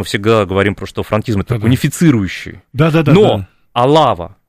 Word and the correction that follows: Алава: Алова, прошу прощения, там ударение на первую букву,